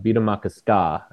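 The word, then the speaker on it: Bde Maka Ska: It was on the banks of the former Lake Calhoun, which has been renamed Beat-a-Maka-Ska. I know some City of Skate followers will want to know that's how you say it.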